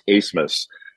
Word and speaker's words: Abmas: You remember Oral Roberts beat Ohio State a couple years ago, and they still have the same guard, Max Asmus, spelled A-B-M-A-S, and it's pronounced Asmus.